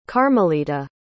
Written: carmelita

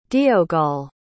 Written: deogol

